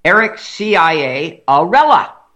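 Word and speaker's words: Ciarella: Eric Chiarella. Eric Chiarella. And I like to pronounce it the correct way. The first three letters of his name make the acronym CIA. Eric CIA Arella. Rhymes with Cinderella.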